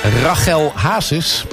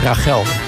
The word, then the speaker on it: Rachel Hazes: En Rachel Hazes. Zo moet je dat uitspreken volgens mij, hè? Rachel.